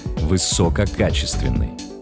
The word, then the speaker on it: высококачественный: Знаете ли вы, как правильно ставить ударение в слове «высококачественный»? Да, именно так. Только на «а». Многие ставят ударение на первое «о». Ошибку эту иногда делают даже корреспонденты, дикторы и радиоведущие. Порой с ударением на «о» это слово произносят и самые образованные люди. Но это неправильно. Орфоэпический словарь русского языка как государственного разрешает только один вариант.